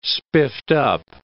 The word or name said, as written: spiffed-up